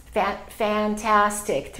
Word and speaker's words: fantastic: So if we say fantastic, three syllables. So the second syllable is going to be stressed.